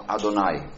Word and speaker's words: Adonai: Entonces el nombre Yud-Hei y Vav-Hei, que se pronuncia en hebreo, cuando lees hebreo, cuando lees un texto bíblico, se pronuncia como Adonai, que significa Señor.